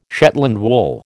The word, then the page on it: Shetland wool: American English